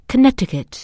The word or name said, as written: Connecticut